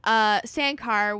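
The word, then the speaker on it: Sancar: Sankar. – It's S-A-N-C-A-R. – Fair enough. – I don't know. But Sankar was a vampire of Turkish descent who was aiming to create a harem of vampires.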